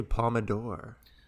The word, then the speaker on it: Palme d'Or: Yeah, I say Palme d'Or.